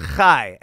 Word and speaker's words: chai: It's pronounced Chai, and it means life.